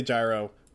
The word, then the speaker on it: Gyro: But yeah, I always say Gyro. The kana backs up Gyro. His full name starts with a hard J.